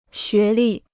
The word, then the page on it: 学历: xué lì